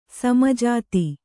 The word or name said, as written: ಸಮಜಾತಿ